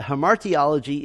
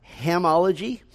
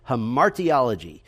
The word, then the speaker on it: hamartiology: So it is not hamology, uh, it is not hamatiology, um, so the easiest way to remember is to put the emphasis on the second syllable, hamartiology.